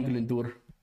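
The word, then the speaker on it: Glyndŵr: Owen Glendower, or Owen Glendour, to pronounce it correctly.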